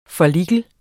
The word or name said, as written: follikel